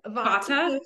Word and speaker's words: Vata: I think I was I don't know if I'm saying it right, but Vata.